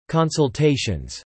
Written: consultations

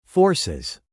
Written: forces

